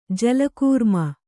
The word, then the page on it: ಜಲಕೂರ್ಮ: jala kūrma